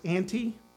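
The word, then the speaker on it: anti (ἀντί): In the Greek, it's pronounced anti. It's spelled A-N-T-I. Anti is how we would pronounce that.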